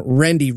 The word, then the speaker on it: Rendy: Ronald and Rendy Wright. Yes, I pronounced that correctly. Her name is Rendy with an R.